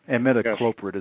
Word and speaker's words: imidacloprid: Imidacloprid is the way you pronounce it.